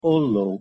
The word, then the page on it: ollo: Hungarian